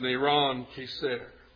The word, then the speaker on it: Neron Kesar: The Hebrew spelling of his name is pronounced Neron Kesar.